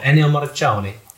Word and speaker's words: Ennio Morricone: So this week, in honour of Ennio Morricone, it's apparently how it's pronounced, my Italian friend told me, I am going to be humming 5 classic movie pieces from soundtracks.